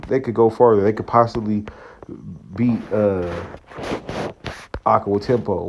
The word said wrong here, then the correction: Antetokounmpo: What's the name? My bad. Giannis Antetokounmpo.